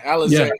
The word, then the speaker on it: Alizé: I would say Alizé is am I pronounced that right, finally? – Yeah, Alizé.